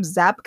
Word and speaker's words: Zabka: Like he, and like, I was reading that William Zabka, mm-hmm. that's how you say it, who's Johnny, didn't have, he'd wrestled, but he had no martial arts training.